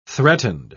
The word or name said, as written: threatened